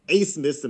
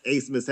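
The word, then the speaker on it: Abmas: If anybody remembers previous tournament performances from Max Asemus, and make sure you get that pronunciation right because it's spelled with a B for some reason, but Max Asemus can score.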